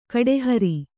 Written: ಕಡೆಹರಿ

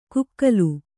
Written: ಕುಕ್ಕಲು

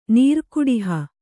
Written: ನೀರ್ಕುಡಿಹ

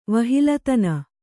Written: ವಹಿಲತನ

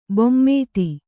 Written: ಭೂಮಿತಿ

